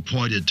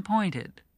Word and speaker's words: pointed: And that the past tense of point is pointed. You add an extra syllable. Point, pointed.